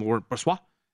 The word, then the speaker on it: Laurent Brossoit: Laurent Brossoit, if that's the case. So that was a beautiful pronunciation, by the way.